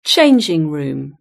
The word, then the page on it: changing room: British English